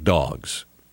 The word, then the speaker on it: Dawgs: But you're going to have to wait for it for just a minute because I want to tell you first about Dogs. Dogs is spelled D-A-W-G-S, and they sell shoes.